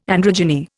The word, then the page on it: androgyny: Indian